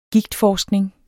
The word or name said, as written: gigtforskning